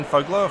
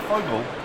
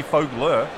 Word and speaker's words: Fogler: But it's got an R at the end. – Well, it's not Fogel, because there's an R at the end. So it might be Fogler.